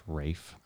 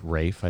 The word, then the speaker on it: Ralph: I think he says it Rafe. I think he's from some European country, but anyway. – Okay, I'll look it up. – I mean, like I said, I'm pretty sure it's spelled Ralph. But anyway, point being, there's a scene in that movie.